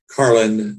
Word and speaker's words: Carlen: It can be found on Amazon, and it could be found under the title name, A Path Revealed, or under my name, Carlen Maddox, which is spelled C-A-R-L-E-N, Maddox, M-A-D-D-U-X.